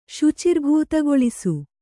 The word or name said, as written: ಶುಚಿರ್ಭೂತಗೊಳಿಸು